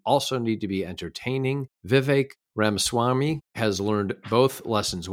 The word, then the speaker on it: Vivek: Politico wrote, Vivek, learn how to pronounce his name. It rhymes with cake. So I guess they're saying that his name is pronounced Vivek. Is that right? – That is correct.